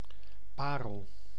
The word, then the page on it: perle: [yn pɛʁl]; /pɛʁl/